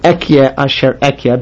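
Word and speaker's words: Ehyeh asher ehyeh: Now here, the next word, we pronounce it Ekye Asher Ekye because it's being used to mean Hashem's name.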